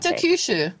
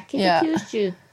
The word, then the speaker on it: Kitakyushu: How do I say that? – Kitakyushu. – Yeah, Kitakyushu. Yeah.